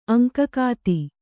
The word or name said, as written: ಅಂಕಕಾತಿ